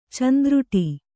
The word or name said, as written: ಚಂದ್ರುಟಿ